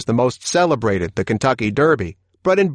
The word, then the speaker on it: Derby: For the 238th time, the Derby, pronounced in the U.K. as the Derby, will be run at Epsom Downs in Surrey, just outside London.